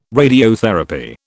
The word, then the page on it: radiotherapy: British English